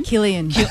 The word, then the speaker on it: Cillian: Killian.